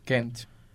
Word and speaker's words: Gent: Da onde é esse time? Da Bélgica. Da Bélgica. Sotaque belga, Gent. cara. Tem que usar o sotaque certo, belga.